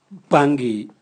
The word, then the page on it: bwangi: Raga